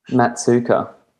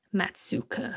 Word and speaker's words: Maatsuyker: They pronounce Maatsuyker. – How would you have pronounced that? – Maatsuyker. – Maatsuyker. I think it's Dutch. So we could have, I think Maatsuyker Island was named after a Dutchman. And so I suspect, or a Dutch person. So I suspect, however we've translated into the Aussie twang, it's probably butchered. – Yeah. Yeah, that's fine. If all the Australians agree that we're saying Maatsuyker, then I'm okay with it.